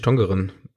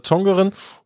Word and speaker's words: Tongeren: Es gibt beide Schreibweisen da irgendwie, und so ganz haben wir nicht aufgedeckt, wann man welche nutzt, aber ich würde jetzt einfach mal niederländisch Tongeren aussprechen und nicht Deutsch. – Genau, wir nennen es einfach Tongeren, und in Tongeren steht tatsächlich ein Lost Ground.